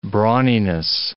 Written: brawniness

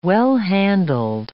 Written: well-handled

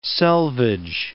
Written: selvedge

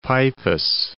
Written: Peipus

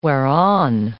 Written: whereon

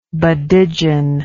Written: badigeon

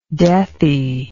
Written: deathy

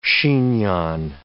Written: chignon

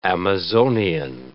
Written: Amazonian

